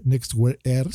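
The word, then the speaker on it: NXTWEAR AIR: Pues bueno, una compañía que yo no me la esperaba, que se llama TCL, TCL, lanza estos nuevos lentes que se llama Next Wear Air. Se escribe NXT Wear Air, ¿no? Next Wear Air,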